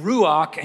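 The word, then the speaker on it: ruach (רוח): And ruach, and you're not saying it right unless like some spittle comes out of your mouth at the end.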